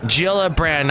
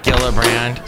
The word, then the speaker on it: Gillibrand: Yes, I meant Gillibrand, not Gillibrand.